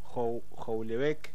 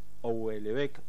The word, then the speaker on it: Houellebecq: Y ahora vamos a hablar de otro libro, que es un libro que yo no había leído nada de Michel Houellebecq, o Houellebecq, sé que la H no se pronuncia en francés, por lo menos en los apellidos, creo, que es una novela del 2015 que fue bastante polémica, ¿no?